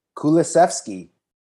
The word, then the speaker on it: Kulusevski: Kulusevski.